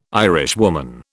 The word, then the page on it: Irishwoman: British English